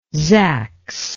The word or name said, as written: zax